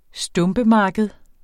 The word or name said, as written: stumpemarked